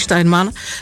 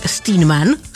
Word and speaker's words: Steinman: Normálně bych to řekla německý Steinman, ale Američani to mají trošičku jinak, takže Steinman se to správně vyslovuje, jsem se dozvěděla, takže asi tak.